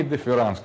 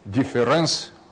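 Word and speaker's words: Differenz: то пишется так, difference, это немецкое написание латинского слова, но лучше произносить его так, а не difference, как у Дериды,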